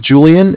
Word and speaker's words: Julian: All right, my name is Julian. It's J-U-L-I-A-N.